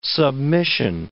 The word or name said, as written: submission